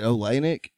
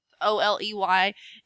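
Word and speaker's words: Oleynik: Is it Olenek? – Yep. Oleynick, I think.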